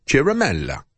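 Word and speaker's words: Ciaramella: I've been calling him Eric CIA Ramella, also pronouncing his name, Chiramella.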